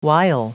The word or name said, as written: wile